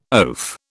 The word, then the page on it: oaf: British English